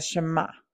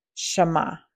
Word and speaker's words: Shema: When you back up to verse 4, the very first word spoken is hear, Shema. If I'm saying that correctly, it's S-H-E-M-A.